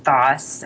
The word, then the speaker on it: Thoss: I have a surname, Thoss. It's a German name and it's spelled in the American spelling is T-H-O-S-S.